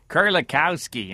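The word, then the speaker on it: Kerlikowske: It's Kulikowski, you know? That's your Polish kind of Chicago American accent there, Kulikowski. But, of course, if you're in war, so it would be Kulikowski.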